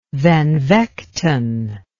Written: Van Vechten

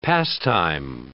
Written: pastime

